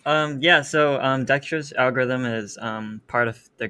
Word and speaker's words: Dijkstra's: Dijkstra's algorithm. – Dijkstra's, yeah.